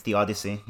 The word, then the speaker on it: theodicy: It's a sort of, and again, philosophy adjacent podcast, it's sort of a theodicy. Is that how you say it in English? I've never actually said this word before in English. – It's theodicy, yeah.